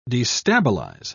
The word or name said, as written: destabilize